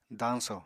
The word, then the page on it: dañsò: apatani